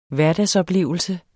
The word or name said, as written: hverdagsoplevelse